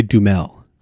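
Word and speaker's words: Duhamel: Yeah, played by Josh, how do you say his last name? Dehamina? – I looked this up just before we got online, and I saw people say Dumel.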